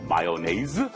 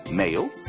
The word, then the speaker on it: mayonnaise: Mayonnaise, mayo, mayonnaise. 하지만 영어 발음은 mayonnaise 아니면 mayo.